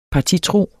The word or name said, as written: partitro